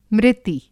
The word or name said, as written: मृति